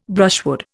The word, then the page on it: brushwood: Indian